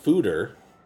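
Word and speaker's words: foeder: Well, according to Deschutes, their website, they say a foder, pronounced fooder, so it's fooder.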